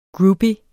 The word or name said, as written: groupie